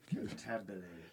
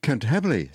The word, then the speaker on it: Cantabile: Cantabile. – Cantabile.